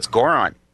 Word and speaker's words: Goron: I feel like we're going to get to the next temple and you guys will be like, "Yes, I got the Garan mask." And I'll be like, "Ah, oh, shoot." – Goron. Rhymes with moron.